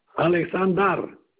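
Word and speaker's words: Aleksandar: Alexander et Gordana. – Ça s'écrit comment? – A L. – Oui. – K. – K. – A L K. – S A N D A. – Alexandra. – Alexander.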